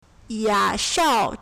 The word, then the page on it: 雅韶: yǎsháo